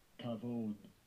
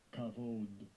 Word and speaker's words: Kavod: Kavod. Kavod.